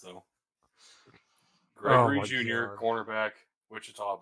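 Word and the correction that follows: Ouachita: Baptist in the sixth round. – Yes, no, that's Ouchita Baptist.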